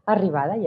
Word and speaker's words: Arribada: So I'm holding up uh, your book, Arribada. Did I say that right? Arribada, yes. Arribada.